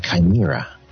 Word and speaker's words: chimera: When you take two different species and you create a new creature, a chimera. Am I pronouncing that correctly? I think so. – Yes, that's it.